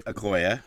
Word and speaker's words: Okoye: Okoye, yeah. – Okoye.